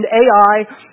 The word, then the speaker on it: Ai: From there he went on toward the hills east of Bethel and pitched his tent with Bethel on the west and Ai, and that's the correct pronunciation of that, as John mentioned, Ai, on the east.